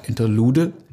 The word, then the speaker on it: Interlude: Spricht man das so aus? Interlude? – Ja.